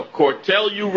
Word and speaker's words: Cortelyou: We're broadcasting live from Vox Pop Coffee Shop and Bookstore at, now how do you say this, Cortelyou or Cortelyou? – No, Cortelyou Road.